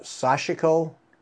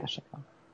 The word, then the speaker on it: Sashiko: I call it Sashiko, and I know that's wrong. – Right. – Sashiko. Yeah, you got to get the emphasis on the right syllable, as they say.